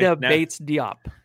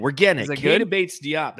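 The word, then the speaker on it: Keita Bates-Diop: Kata Bates Diop. – Kata Bates Diop. Yeah. Okay. Yeah. We're getting it. Kata Bates Diop.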